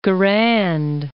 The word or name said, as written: Garand